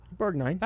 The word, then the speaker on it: Borgnine: Borgnine.